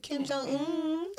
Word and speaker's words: Kim Jong-un: What's that one more time? – Kim Jong-un. – Kim Jong-un. How you say it? – Kim Jong-un.